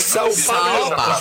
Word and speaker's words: São Paulo: ¿Escuchaste cómo se pronuncia a ver? – Sao Paulo. Sao Paulo. O sea, ahí no quiero escuchar que diga San Pablo.